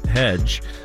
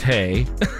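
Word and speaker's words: hej: I was going to say hedge, but it's pronounced hay.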